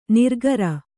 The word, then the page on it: ನಿರಾಕಾರ: nirākāra